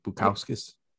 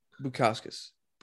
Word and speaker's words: Bukauskas: Before I butcher Bukauskas. – Bukauskas.